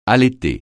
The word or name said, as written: allaiter